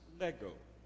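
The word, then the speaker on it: lego (λέγω): The word say in the Greek is a little word we pronounce lego. You ought to be able to remember that. It's just like the toys the little kids and my grandson puts together, you know, legos, l-e-g-o.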